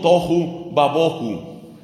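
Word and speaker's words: tohu babohu (תֹהוּ וָבֹהוּ): La expresión hebrea se pronuncia algo así como tohu babohu.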